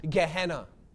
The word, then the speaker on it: Gehenna: This word in the Greek for hell is pronounced Gehenna.